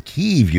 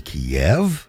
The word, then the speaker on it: Kyiv: Kyiv used to be Kiev.